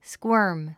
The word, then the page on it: squirm: skwə’ːrm; スクワーム